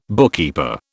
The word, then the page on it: bookkeeper: British English